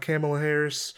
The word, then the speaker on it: Kamala Harris: And also, I talk a little bit about Kamala Harris.